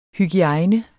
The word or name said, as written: hygiejne